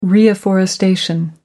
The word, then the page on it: reafforestation: American English